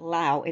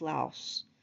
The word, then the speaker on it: Laos: All of a sudden, I decided I should look up the correct pronunciation for the country they're going to, and it's not Laos, it's Laos. That's at least the best my Texan accent can handle it.